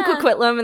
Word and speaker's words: Coquitlam: Shout out to Haley, our friend Haley, who's from Coquitlam, and that's the only reason I knew how to say it.